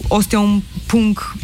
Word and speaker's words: Ostia un Punk: Tale zadnji komad Ostia un Punk v bistvu govori o dehumanizaciji alternativnih subkultur, se pravi Ostia un Punk, izgovarjam to tako, ker Španci tako grozen izgovarjajo,